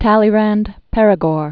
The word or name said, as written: Talleyrand-Perigord